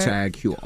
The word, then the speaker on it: Tag Heuer: Tag Heuer.